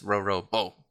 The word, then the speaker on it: Roro Bo: His name is Roro Bo. – Roro Bo? – Yes, you must put the emphasis on the end, or he gets very angry.